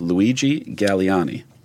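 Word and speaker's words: Galleani: But they're both believed to be, and this is where we're going to need you, Uncle Paulie, the pronunciation of this one, I will screw it up. Both are believed to be followers of a man by the name of Luigi Galliani. – That's correct.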